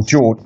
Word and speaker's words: Jorge: Very, very exceptional circumstance, and they've got that Jorge Mendes or George. Actually, it's George because it's Portuguese. Hard J. George Mendes.